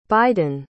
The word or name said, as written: Biden